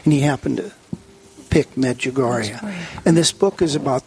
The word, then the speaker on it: Medjugorje: And I had it took me about 2 years to try to say it right. – Yeah. – Medjugorje, Yugoslavia at the time in 1990.